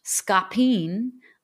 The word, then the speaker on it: Scapin: I directed a play, Scapin, also pronounced scapine.